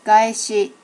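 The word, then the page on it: gaeshi: native Japanese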